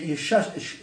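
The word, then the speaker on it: Shestack: Your name, Shestak. – Shestak, yeah. – Yeah, 1 second. Spell it again, first name. – Eli. – E-L-Y. S-H-E-S. T-A-K. – C-K. – T-A-C-K.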